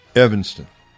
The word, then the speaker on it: Evanston: Evanston.